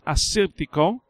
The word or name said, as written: Assyrtiko